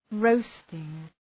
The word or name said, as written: roasting